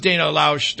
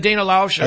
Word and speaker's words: Loesch: That was Dana Loesch. Did I say it right? Is that Dana Loesch? – I think so.